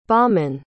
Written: Beomann

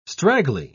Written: straggly

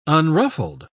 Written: unruffled

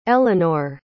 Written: Ellinor

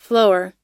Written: flor

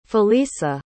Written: felisa

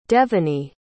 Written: devenny

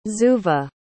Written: zuva

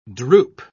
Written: drupe

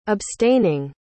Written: abstaining

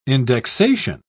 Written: indexation

